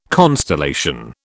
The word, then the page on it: constellation: British English